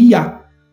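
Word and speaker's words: Yah: Quando se separa o Yud Re do Vav Re, aí se pronuncia Iá.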